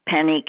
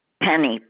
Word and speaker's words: Penny: Penny Cave. – Can you spell that last name? – C-A-V as in Victor, E. – So C-A-V-E? – Right. – And the first name is K? – Penny, P-E-N-N-Y.